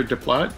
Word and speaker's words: Desplat: Is it Alexander Desplat? Desplat? – What's your final answer? – Alexander Desplat. I can spell it. – Spell it, please. – D-E-S-P-L-A-T. – That is correct.